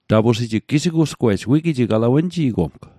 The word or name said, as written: gisigu'sgwe'j